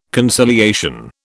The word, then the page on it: conciliation: British English